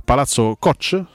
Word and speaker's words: Palazzo Koch: Un'ulteriore segnalazione giunta a, Palazzo Koch, si pronuncia così,